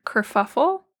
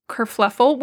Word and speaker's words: kerfuffle: We had a little incident the other day where I didn't know how to pronounce the word kerfuffle. I thought it was kerfluffle, which... By the way, I'm not the only one who thinks it's kerfluffle.